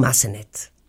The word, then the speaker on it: Massenet: Él va desde un Donizetti hasta un Rossini, desde un Bellini hasta un Puccini, desde un Mozart hasta un Verdi, pasando por un Massenet. ¿Lo dije bien? – Muy bien.